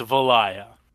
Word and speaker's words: Valaya: Valaya. – But there's a K in it. – Hey, one of us knows how to say the names, and that's how Barden from Vermintide says it. So it's Valaya.